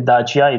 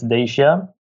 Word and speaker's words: Dacia: We had our, we pronounce it Dacia, it's Dacia car.